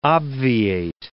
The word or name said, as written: obviate